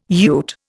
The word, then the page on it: ute: Indian